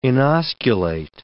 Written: inosculate

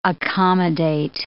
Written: accommodate

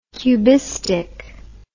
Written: cubistically

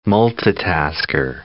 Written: multitasker

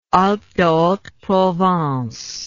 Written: Alpes-de-Haute-Provence

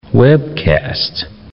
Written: webcast

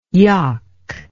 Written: yok

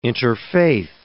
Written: interfaith

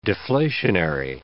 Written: deflationary

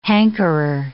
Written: hankerer

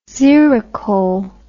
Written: xerocole

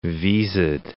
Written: visaed

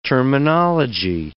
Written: terminology

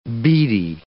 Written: bidi

